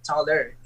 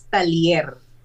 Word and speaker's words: Taller: Uh, the real pronunciation of my surname is not taller. It is Talier.